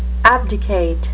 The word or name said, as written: abdicate